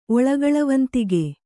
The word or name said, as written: ಒಳಗಳವಂತಿಗೆ